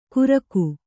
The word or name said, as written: ಕುರಕು